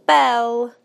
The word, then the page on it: bell: British English